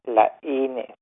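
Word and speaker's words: Laínes: Laines has an accent mark in the letter I, so the pronunciation is Laines.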